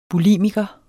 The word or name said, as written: bulimiker